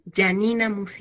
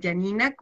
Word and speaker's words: Gianina: Mi correo, que siempre lo nombramos acá porque se escribe como mi nombre, Janina pero Janina con G de gato. Y con una N nomás, Gianina.